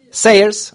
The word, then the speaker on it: Sayers: Jag vill sluta med ett citat från den engelska teologen, tänkaren och deckarförfattaren eller författarinnan Dorothy Sayers, eller Sawyers, jag vet inte hur hennes efternamn uttalas. Sawyers. Sayers, okej, okay.